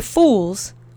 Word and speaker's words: fools: W słowie fools o wymawia się jako u.